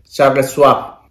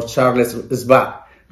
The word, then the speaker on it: Charles Schwab: Y la última acción de este video sería la acción de Charles Schwab o Charles Schwab, dependiendo de dónde estén, si están en Alemania, porque la W se pronuncia como B.